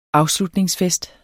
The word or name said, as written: afslutningsfest